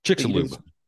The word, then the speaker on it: Chicxulub: Chicxulub.